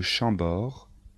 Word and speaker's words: Chambord: Les plus célèbres sont les châteaux de Chambord, de Blois, de Chenonceau et le château d'Amboise. Attention de bien prononcer les noms de châteaux. Répétez après moi.